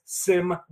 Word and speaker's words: symbiote: The correct pronunciation is symbiote.